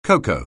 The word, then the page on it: cocoa: anglais